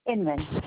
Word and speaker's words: Inman: D-I-A-N-N. – Yep, that's it. No, D-I-A-N-A. – Okay. And what's your last name? – Inman. – Spell it for I-N-M-A-N. – I-N-M-A-N.